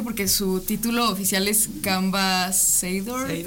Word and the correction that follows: Camba Ambassador: ¿Camba Ambassador? – Pues es como, como embajadora.